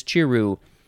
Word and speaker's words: chiru: Next we have chiru. Chiru. It's pronounced just like it's spelled. C-H-I-R-U. Chiru.